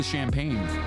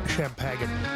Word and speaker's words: Champagne: But it is the champagne. – Champagne. – Oh, is that how it's pronounced? – Yeah, it's actually Champagne. – Champagne. I've been saying it wrong this entire time.